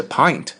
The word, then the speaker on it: pint: 所以这里品托注意的，它发音是 pint。